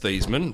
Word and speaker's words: Theismann: Theismann, whose last name was actually pronounced Theismann, recounted in 2007 that it was Notre Dame's publicity man, Roger Valdesteri, who insisted that he changed the pronunciation of his name to rhyme with Heisman.